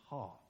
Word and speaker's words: HART: H-A-R-T, pronounced heart.